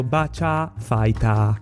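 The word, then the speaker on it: Bacia Fighter (バーチャファイター): La pronuncia della parola Virtua in tutta sua, in giapponese è scritto Bacia Fighter, quindi loro lo pronunciano Bacia Fighter, non in altro modo.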